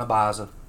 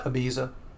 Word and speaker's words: Ibiza: That just hit like a roll in a biza. – Like a roll in a what? – Ibiza.